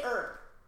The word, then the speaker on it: herb: It's an air. Say herb. – Herb. – It's an herb.